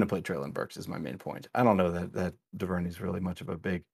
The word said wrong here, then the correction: Duvernay: No, I think it's pronounced Duvernay. Duvernay.